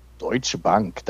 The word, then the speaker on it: Deutsche Bank: Deutsche Bank. That's how you say it. You have to say it with a little, like, German accent.